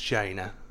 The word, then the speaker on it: Jaina: It's pronounced Jaina, as far as I know. I think I've said that before. – No, you're correct.